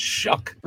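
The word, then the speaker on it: Shough: Yeah, I have trouble trusting anybody whose name is spelled show, but it's pronounced Shuck.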